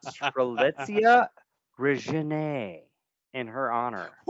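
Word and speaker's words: Strelitz: It's Strelitzia. Say it with me now, kids. – Don't tell us how to pronounce it. Strelitzia.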